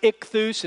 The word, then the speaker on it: ichthus: It is it's pronounced ichthus, and ichthus is an acronym that stands for the Greek way of saying Jesus Christ, God's Son, Savior.